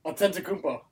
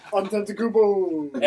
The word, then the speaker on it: Antetokounmpo: Antetokounmpo. – Just did it. Giannis! – Antetokounmpo.